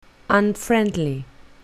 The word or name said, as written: unfriendly